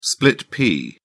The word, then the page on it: split-pea: British English